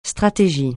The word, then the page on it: stratégie: francais